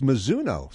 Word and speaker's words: Mizuno: You had dinner with, how did you pronounce that? Because here it's Mizuno Sports. – Well, it's lost in translation. It's Mizuno. – Mizuno.